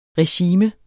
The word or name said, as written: regime